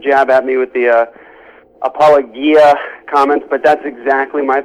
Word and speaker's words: Apologia: I know that our friend, our mutual friend, James White, always makes fun of you mispronouncing Apologia, which he says it should be rightly pronounced Apologia.